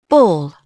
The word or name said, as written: ball